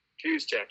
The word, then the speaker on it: Juszczyk: Juszczyk, yes.